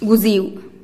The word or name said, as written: guziu